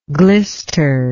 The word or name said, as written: glisteringly